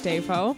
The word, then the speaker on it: Defoe: My name's Grace Defoe. – Defoe, I like that. – Emphasis on the day.